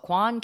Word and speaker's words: Kwan: My last name is spelled Kwan, K-W-A-N, Melissa Kwan.